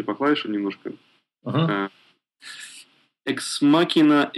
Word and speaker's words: Ex Machina: Эксмакина, вроде как правильно произносится.